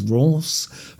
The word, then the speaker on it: Reims: My French friends have taught me how to pronounce this as Ross.